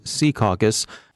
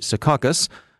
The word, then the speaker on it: Secaucus: Our North Jersey desk, by the way, insists that we use the old-school local pronunciation Secaucus as opposed to the trendy Secaucus, favored by recent arrivals who lack knowledge but do watch football games over at the Meadowlands.